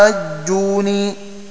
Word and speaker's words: أتحاجوني: ابن ذكوان ونافع وهشام بخلف عنه، فينطق على هذه القراءة بنون واحدة مخففة مكسورة وبعدها الياء الساكنة هكذا: أتحاجوني، وشددها الباقون، وهو الوجه الثاني لهشام.